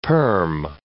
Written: perm

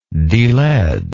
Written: delead

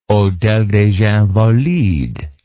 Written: Hôtel des Invalides

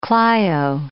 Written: Clio

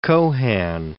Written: Cohan

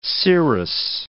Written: scirrhous

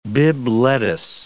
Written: Bibb lettuce

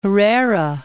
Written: Herrera

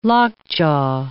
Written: lockjaw

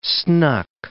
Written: snuck

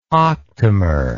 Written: octamer

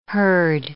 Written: herd

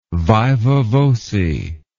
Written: viva voce

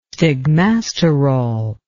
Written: stigmasterol

